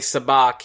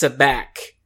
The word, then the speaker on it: Sabacc: because when Han goes in to play Sabacc, he says, "Is this Sabacc?" And Lando says, "Sabacc." – Right. – And then he starts calling him Han. He is intentionally so like Han is mispronouncing the name of the game to try to look like a rube.